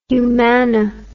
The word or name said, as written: Humana